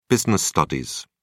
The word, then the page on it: business studies: British English